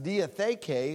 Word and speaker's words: diatheke: and that's pronounced diatheke, occurs 32 times in the Greek New Testament.